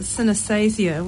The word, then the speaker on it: synesthesia: And it's about synesthesia, which is. Is that how you say it? – Yeah.